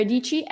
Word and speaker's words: Dietschy: How should people follow Sara Dietschy rhymes with peachy? – Yeah. So my channel is just my name, Sara Dietschy, SaraDietschy.